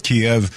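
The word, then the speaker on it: Kyiv: Kiev is the Russian pronunciation. And to show people, kind of like virtue signal, they're going with a Ukrainian Kiev as opposed to Kiev.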